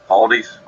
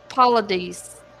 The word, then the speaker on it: Paulides: But David Paulides. – Paulides.